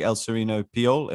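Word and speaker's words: Elserino Piol: Is it true that you cold called one of the biggest, I mean, the biggest venture capitalist in Italy, El Sereno Piol? Am I saying that right? Is that his name? – Yeah, you're saying right.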